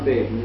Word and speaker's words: Baydon: But we know Baden because we used to go there, and it's, you go up to Baden, it's spelled B-A-Y-D-O-N actually, but you go up a hill, and then you get a magnificent view looking down.